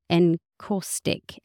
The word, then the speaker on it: encaustic: Glass, stone and encaustic. Encaustic, is that how you say it? – Yes.